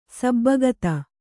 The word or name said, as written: ಸಬ್ಬಗತ